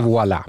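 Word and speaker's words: Woilà: W-O-I-L och sen ett sånt här franskt A. Jag vet inte exakt hur det ska uttalas, men något i den stilen. – Vi säger Voila.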